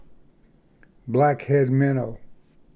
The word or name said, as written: blackhead minnow